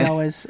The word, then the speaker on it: Elwes: Carrie Elwes, is that how you pronounce it? – Yeah, Carrie Elwes.